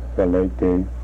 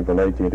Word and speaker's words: Vilatte: Valete. That's the way I'm pronouncing his word. He spells it capital V-I-L-A-T-T-E.